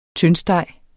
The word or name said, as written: tyndsteg